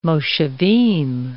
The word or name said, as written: moshavim